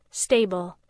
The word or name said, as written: stable